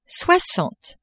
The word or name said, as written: soixante